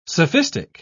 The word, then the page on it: sophistic: British English